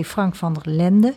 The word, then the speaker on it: Frank van der Lende: Ze zouden van 6 tot 7 een live radioprogramma maken samen met 3FM-DJ Frank van der Lende. Zeg ik het goed, hè? – Ja.